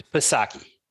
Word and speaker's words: Psaki: You know, the P is silent. It's Psaki. – Oh, that's what I thought. I thought it was Psaki, but it's not. Yeah. It's Psaki. – Psaki.